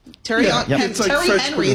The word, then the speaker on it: Thierry Henry: And they also hired, is it Thierry Henry? – Yeah. – Is this how you say Thierry. – It? Terry Henry, the American way, former star player as its director of football.